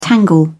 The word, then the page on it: tangle: British English